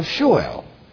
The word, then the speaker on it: Sheol: See, that phrase, the land of gloom and deep shadow, is a description of Sheol, the place of the dead. S-H-E-O-L, so just in case you don't know what I'm pronouncing, Sheol.